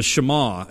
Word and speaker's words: Shema: And in Deuteronomy 6, verse 4, this passage is actually called the Shema, or the Shema is probably the more proper way to pronounce it.